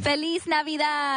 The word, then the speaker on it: Feliz Navidad: Feliz Navidad.